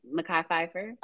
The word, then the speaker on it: Mekhi Phifer: Mekhi Phifer.